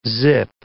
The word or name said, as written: zip